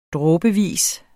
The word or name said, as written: dråbevis